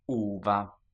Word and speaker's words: uva: Uva. – So that's two syllables.